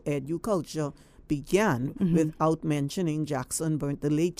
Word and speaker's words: EduCulture: EduCulture, yes.